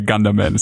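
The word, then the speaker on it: Gundermann: Ja, zuallererst ähm, muss ich mich entschuldigen, Steve hat hier den Namen ganz klar ungefähr 800 Mal falsch ausgesprochen. – Ja. – heißt er, natürlich heißt er Gunderman, ist ja ein deutscher Film.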